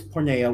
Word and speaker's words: porneia: But it's pronounced it's porneia, which means basically illicit sexual intercourse, fornication, adultery, homosexuality, intercourse with animals or close relatives or with divorced person.